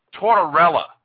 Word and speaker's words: Tortorella: How do you pronounce his name? – Tortorella. Like mozzarella, Tortorella.